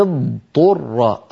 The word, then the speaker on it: اضطر: بقي كلمة اضطر، هذه يكثر فيها الخطأ في الآية 73 بعد 100، فمن اضطر، الضاد صوتها مستقل عن الطاء